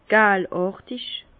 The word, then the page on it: galàrtig: Bas Rhin; Herrlisheim